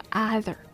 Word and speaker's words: other: 第一点是元音的发音。虽然它是 O 开头的，讲中文的朋友会说 other, 但不是 A 的音。